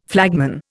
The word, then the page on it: flagman: Indian